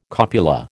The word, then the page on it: copula: American English